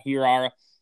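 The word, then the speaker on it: Hiura: Keston, how do you pronounce his last name? Ooh. From Milwaukee, second baseman. Hira. Yeah.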